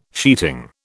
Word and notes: sheeting: British English

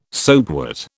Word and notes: soapwort: British English

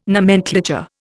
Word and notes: nomenclature: Indian

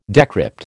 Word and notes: decrypt: American English

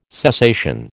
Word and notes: cessation: American English

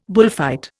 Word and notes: bullfight: Indian

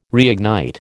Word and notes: reignite: American English

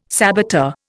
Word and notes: saboteur: Indian

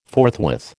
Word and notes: forthwith: American English

